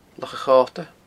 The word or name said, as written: Loch a' Chota